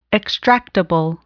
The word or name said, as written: extractable